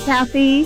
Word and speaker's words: Cathy: Kathy or Cassie? Kathy, C A T H Y.